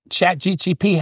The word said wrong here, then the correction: ChatGPT: ChatGPT. You always say it wrong.